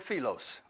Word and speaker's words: philos: The word friend here is the Greek word philos, okay? Philos. Phonetically, it's spelled P-H-I-L-O-S, philos.